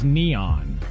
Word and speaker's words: N3on: I think that you don't know because his name is Neon. – No, it's... It's spelled N, the number 3-O-N. N3on. – Yeah, but he goes by Neon.